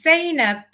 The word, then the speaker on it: Zayna: It's Zayna, not Imran. It's Iman, not Ash.